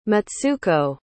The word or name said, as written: matsuko